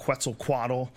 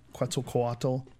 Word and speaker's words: Quetzalcoatl: The Return of Quetzalcoatl. Is that how do I say it right? – Good enough. – Good enough? Close enough? Quetzalcoatl.